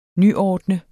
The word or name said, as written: NYORDNET